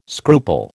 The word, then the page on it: scruple: American English